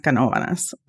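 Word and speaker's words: Canóvanas: So it's actually pronounced Canovanas.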